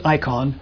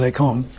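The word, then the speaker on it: eikon (εἰκών): We pronounce it icon. I believe the Greek pronunciation is eikon.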